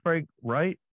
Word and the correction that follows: Frank Reich: Frank Reich. – Reich. – Frank Reich. – Sorry. – Yeah. – Like Heidenreich.